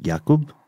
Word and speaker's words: Jakub: Because up until 2005, Clara Marova was a single mother of two, and her two sons, Andrzej and Jacob, or as we talked about off air, perhaps pronounced Jakub. – Mm-hmm. J-A-K-U-B. – Mm-hmm. And Andrzej, O-N-D-R-E-J.